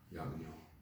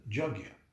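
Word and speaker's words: yajña: Yagya. – Yagya. – Yagya, yeah. – You know, it's like a... It would read Yajna, but it's right. Yagya. Actually, Prabhupada always... Many people pronounce it Jagya.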